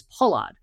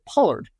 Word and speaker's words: Pollard: My last name is Pollard, but everyone here pronounces it Pollard, right? Because they pronounce the R so much stronger.